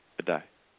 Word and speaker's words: Ajayi: Ajayi. Is that his Ajayi. – Name? Ajayi.